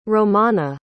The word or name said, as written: romana